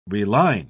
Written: reline

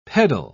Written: peddle

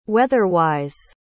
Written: weather-wise

